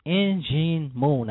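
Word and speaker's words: In Jin Moon: It's Injin Moon. I had to learn how to say it right.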